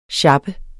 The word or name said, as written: sjappe